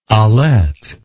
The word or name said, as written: alette